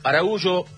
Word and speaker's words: Araújo: Bueno, final del primer tiempo entonces, de Miami, Uruguay 2, Araújo, porque me quedo con la pronunciación portuguesa, que es el origen de ese apellido, y Canovio, los goles para la selección oriental.